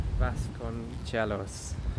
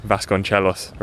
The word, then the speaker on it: Vasconcelos: Vasconcelos. – Vasconcelos, very good.